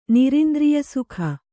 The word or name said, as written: ನಿರಿಂದ್ರಿಯಸುಖ